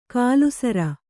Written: ಕಲ್ಲುಸಾರ

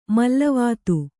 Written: ಮಲ್ಲವಾತು

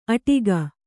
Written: ಅಟ್ಟಗೆ

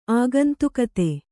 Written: ಆಗಂತುಕತೆ

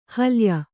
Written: ಹಲ್ಯ

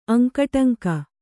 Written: ಅಂಕುಡೊಂಕು

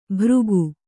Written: ಭೃಗು

